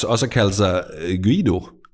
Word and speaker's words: Guido: Jeg ved ikke, hvordan du udtaler det. G-U-I-D-O. Guido.